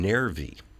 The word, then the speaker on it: Nervi: Old New Dreams live in Nervi, Italy. – Nervi, yes. Nervi. – I don't know where that is. – How do you spell it? – N-E-R-V-I. – Nervi.